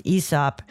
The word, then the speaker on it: Aesop: and that's how it's pronounced, Aesop.